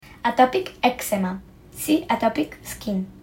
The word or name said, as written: atopic eczema